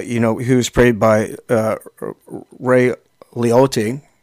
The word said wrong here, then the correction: Liotta: uh, or how, how would you say his name? Ray Liotta.